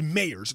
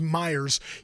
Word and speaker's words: Myers: Mike Myers, it looks like it's Mayers. It's pronounced Myers.